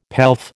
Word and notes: pelf: American English

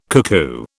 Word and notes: cuckoo: British English

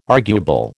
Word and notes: arguable: American English

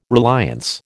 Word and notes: reliance: American English

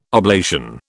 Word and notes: oblation: British English

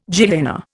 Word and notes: Gehenna: Indian

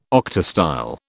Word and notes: octastyle: British English

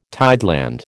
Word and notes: tideland: American English

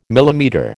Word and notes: millimetre: American English

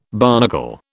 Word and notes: barnacle: British English

